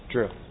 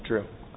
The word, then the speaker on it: Drew: Drew. Drew. – D-R-E? – W.